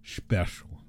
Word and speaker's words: Shpeshal: It's spelled like S-H-P-E-S-H-A-L. Special.